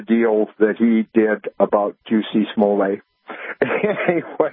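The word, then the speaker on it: Jussie Smollett: Well, the number one is it's pronounced Juicy Smollett. It's a French name. I just, Dave Chappelle said that's how it's to be pronounced, is Juicy Smollett.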